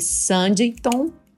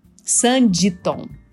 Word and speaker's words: Sanditon: é, então, eu assisti a série Sanditon. Sanditon. Eu tava pronunciando errado.